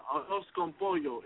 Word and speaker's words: arroz con pollo: It's um, it's a hey, Merlot. It's uh, arroz con pollo. It's um, it's Spanish for rice and chicken.